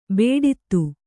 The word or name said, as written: ಬೇಡಿತು